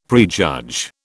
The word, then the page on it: prejudge: British English